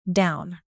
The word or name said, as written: down